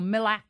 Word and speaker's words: MLAC: Well, it's the Master Locksmith Access Key, MLAC or MILAC for short.